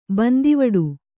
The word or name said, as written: ಬಂದಿವಿಡಿ